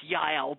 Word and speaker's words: Jael: One thing about Jael is she, and it probably would actually be pronounced Yael, but most of us English-speaking people make the J a hard J, just as we do with Jesus. But Jael is ready for him.